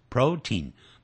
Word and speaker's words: protein: This food is rich in protein, P-R-O-T-E-I-N, P-R-O-T-E-I-N, protein, 不要按照那个字的拼法念成 protein, 不是这样念的啊， protein。